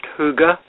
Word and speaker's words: hygge: You have a kind of a hammer headline on here, which I believe is pronounced hygge. And what exactly is hygge? – It's a Danish word, apparently. It's pronounced hygge. Very many different ways possibly of pronouncing it if you're not Danish.